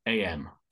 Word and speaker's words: Cottenham: But the YouTube channel where people can leave messages is YouTube / Cotton, which is spelled COTTENHAM, Cottenham.